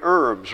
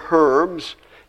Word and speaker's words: herb: Many of us enjoy herbs, right? Or herbs. You can say herb, by the way. Look it up, and it's correct to pronounce it herb or herb.